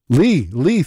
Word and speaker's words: Leigh: L-E-I-G-H. How do you say that? – Lee, thank you.